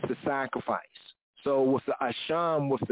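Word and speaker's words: a sham (אשם): Or if you read it in the English way, a shame, a sham, but more correctly pronounced, a sham, the guilt or the trespass offering, right?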